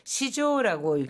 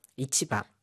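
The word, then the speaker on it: 市場: 이 시장은 시조라고 읽습니다. 이 시장을 시조라고 읽는 것은 추상적인 개념의 시장이라든가 너무너무 큰 규모의 시장, 여론 시장이라든가 증권 시장 뭐 이럴 때는 전부 시조라고 읽죠. 반면에 뭐 남대문 시장이라든가 재래 시장 이런 것들은 전부 이치바.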